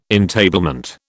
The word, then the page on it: entablement: British English